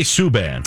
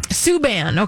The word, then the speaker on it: Subban: Subban. – Subban, okay.